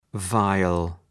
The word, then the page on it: viol: ‘vaıəl